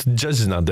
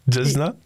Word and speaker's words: Jezna: چشنانی نوی دکتن دورو دی Let's talk about Jezna then. Did I pronounce it right? Jezna? – Yeah, it's good.